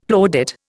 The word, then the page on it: plaudit: Indian